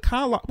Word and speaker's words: Carlisle: Which apparently is now called the Carlisle. Well, it's not. I was told it was the Kalil. And I was told by staff it was the Kalil. And now people are like, no, it's Carlisle.